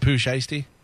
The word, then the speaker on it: Pooh Shiesty: Am I saying that right? Is it Pooh shiesty? – Yeah.